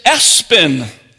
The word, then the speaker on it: ESPN: The name is Espen, or that's the pronunciation. The spelling is E-S-P-N.